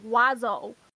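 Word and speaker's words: oiseau: It's pronounced, it's spelled O-U-I. – I also heard that, Bird saying... is pronounced like Wazo. And it doesn't even have a W.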